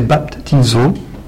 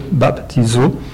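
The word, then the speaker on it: baptizo (βαπτίζω): Le verbe grec des textes originaux du Nouveau Testament, prononcé baptizo, avec le P, baptizo,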